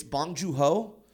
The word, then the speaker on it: Bong Joon-ho: It's even though it's fairly easy, it's Bong Joon-ho.